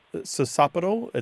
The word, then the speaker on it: sociopetal: For example, I believe the word is pronounced sosopetal. It looks like sociopetal, socio plus P-E-T-A-L.